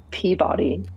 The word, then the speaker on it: Peabody: Peabody.